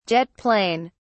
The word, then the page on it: (jet) plane: British English